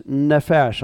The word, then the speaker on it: nephesh: The Hebrew word, okay, the Hebrew word, going back to the Hebrew, for soul is nephesh. I'll spell it for you. N-E-P-H-E-S-H.